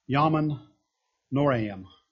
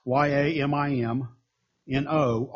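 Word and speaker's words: Yamim Noraim: It's called Yamin Noraim. It's Yamin Noraim.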